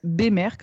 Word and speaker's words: Bemer: donc ça se prononce Bémer comme s'il y avait un accent euh, ah. sur, le, sur le premier.